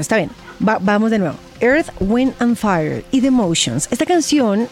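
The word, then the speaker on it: Earth, Wind & Fire: ¿Te gustó la pronunciación o no tanto? – Wind and Fire. Perfecto. Tienes, la pasa 3 veces por programa al grupo. Nico, su opinión. – Earth, Wind and Fire, hombre.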